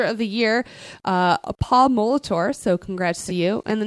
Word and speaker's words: Molitor: It's Paul Molitor.